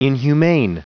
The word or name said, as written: inhumane